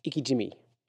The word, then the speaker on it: ikejime: But it actually has a real name, and that real name is called, I said it wrong the first time, it says I-K-E-J-I-M-E. It's got Ikijimi. Ikijimi. I read it as Ike Jime.